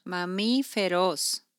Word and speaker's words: mamíferos: Mamíferos. – Mamíferos.